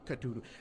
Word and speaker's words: Cthulhu: C'est pas évident à prononcer. Là, il y en a qui le prononcent Cthulhu, mais bref, Cthulhu.